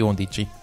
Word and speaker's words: Undici: Undici.